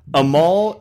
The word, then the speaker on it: Amal: I don't internet well, but when I do, Amal Shaw, I believe is how you say his name, A-M-A-L, last name Shaha, S-H-A-H.